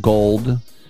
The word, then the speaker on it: Gould: We also have Robbie Gould, 49ers kicker, spelled Gould, but he pronounces it Gold.